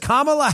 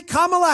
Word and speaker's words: Kamala: Don't mispronounce it. Jim Acosta will definitely get on you. He says Kamala Harris. Kamala, because you call her Kamala, even though they called her Kamala before.